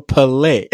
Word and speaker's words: Pillay: Yeah, if people ask me, how do you pronounce that? I go, you know, like fish filet, you know, Pillay.